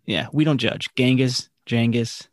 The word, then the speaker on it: Genghis: The topic of our podcast today, Genghis Khan, or as you enlightened me, Genghis Khan. – Yeah, so that's how it is most similarly pronounced to how the Mongols probably pronounce it, is Genghis. But then, you know, we've all said Genghis.